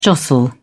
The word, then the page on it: jostle: British English